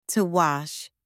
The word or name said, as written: to wash